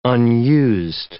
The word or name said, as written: unused